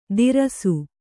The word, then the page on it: ದಿರಸು: Kannada